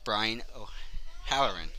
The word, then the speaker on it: O'Halloran: Make sure I pronounce this right, Brian O'Halloran.